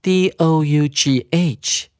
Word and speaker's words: dough: 我们要先记得它的字根 d o u g h，这个字要念成 dough，d o u g h，g h 是不发声的。